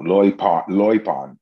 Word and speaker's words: loipon: You know, loipon, if you want to know. It sounds Asian almost, but that's how it's pronounced.